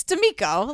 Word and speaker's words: D'Amico: Girl, it's D'Amico.